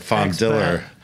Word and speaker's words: Fondiller: It's a hard L. It's like Fondiller.